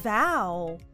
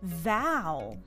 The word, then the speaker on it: vow: 発音に要注意今日はみんなで VOW を言ってみましょう Repeat VOW VOW V の音は上の歯を下の唇に軽くつけて唇を震わせながら音を出してみましょう。